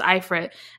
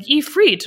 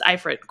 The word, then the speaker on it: Ifrit: Question, how do you say Ifrit? The audio says Ifrit. Okay, so that's how I used to pronounce it. It was Ifrit. And then I, Googled it, YouTubed it, and it was like Ifrit. And I'm like, okay, well, apparently I've been saying this wrong, so it's Ifrit. Cool. I will go back to saying Ifrit.